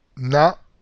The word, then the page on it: na: Spanish